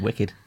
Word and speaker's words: WKD: Is it pronounced wicked? It's pronounced wicked. Wicked. WKD.